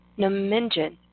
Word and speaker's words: Nominjin: Nominjin. – Nominjin.